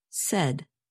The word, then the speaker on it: said: And notice that the past tense of say is pronounced said. You can remember this pronunciation by thinking of words that rhyme with said, such as red and head.